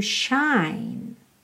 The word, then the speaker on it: shine: Your time has come to shine. All your dreams are on their way, their way. See how they shine. Atenção que o E do shine não é pronunciado, ok? Shine termina o som no N.